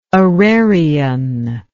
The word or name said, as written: orarion